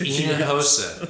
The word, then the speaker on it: Hinojosa: Now that's spelled with an H, but the H is silent. So Hinojosa.